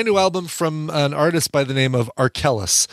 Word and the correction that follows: Arkells: Not to be confused with R. Kelly. Arkellis is spelled A-R-K-E. I'm sorry, Arkells. A-R-K-E-L-L-S. Nice.